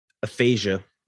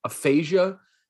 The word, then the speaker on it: aphasia: Diagnosed with Aphasia. – Aphasia.